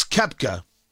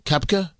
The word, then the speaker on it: Koepka: Brooks Koepka. That's how I pronounce his name, right? The golfer, Koepka? Is that how I do it? Yeah.